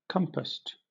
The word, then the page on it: compassed: British English